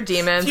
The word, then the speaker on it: daemons: But these are demons. – Future episode. – Yeah. Side note, don't at me. I love his Dark Materials, but they're pronounced demons. That's a fact.